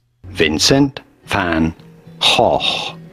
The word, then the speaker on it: Vincent van Gogh: Vincent Van Gogh is how the Dutch say it. – There you go. – So we both have been saying it wrong.